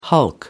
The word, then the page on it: hulk: American English